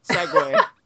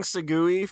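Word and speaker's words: segue: Then how do you say it? – Segway. But Rhett's been saying Segui for a year.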